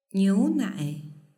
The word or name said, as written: niunai